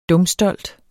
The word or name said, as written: dumstolt